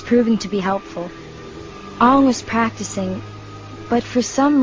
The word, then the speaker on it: Aang: Okay, yeah, Aang. To rhyme with the third syllable in boomerang.